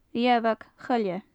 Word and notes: riabhag-choille: gaelic

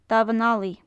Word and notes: damhan allaidh: gaelic

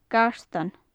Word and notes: gartan: gaelic

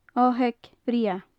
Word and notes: oigheag bhreagha: gaelic

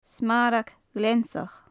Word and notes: smarag ghleansach: gaelic

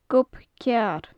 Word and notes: gob cearr: Gaelic

